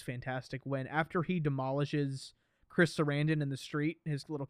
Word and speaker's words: Chris Sarandon: Yeah, I love when you said Chris Sarandon.